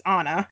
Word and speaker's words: Anne: Anna, which, despite being spelled A-N-N-E, for some reason they all pronounce Anna.